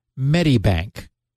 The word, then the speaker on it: Medibank: Michael Lawley, he said, please, at SGGRC, it's pronounced Medibank. And Okay, I'm glad to know that. – We didn't saying, know? How would you know? Right. Medibank.